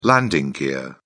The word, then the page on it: landing gear: British English